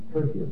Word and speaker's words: Tertius: Tertius. Tertius is how to pronounce.